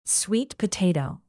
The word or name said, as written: sweet potato